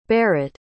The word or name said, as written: barit